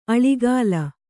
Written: ಆಲಿಗಲ್

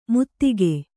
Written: ಮುತ್ತಿಗೆ

